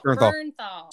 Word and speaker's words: Bernthal: Can we just say that I don't know how to say his last name? – Bernthal.